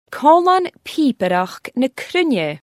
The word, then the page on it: Còmhlan pìobaireachd na cruinne: Scotland